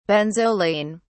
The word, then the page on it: benzoline: British English